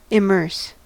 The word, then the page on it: immerse: American English